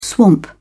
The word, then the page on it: swamp: British English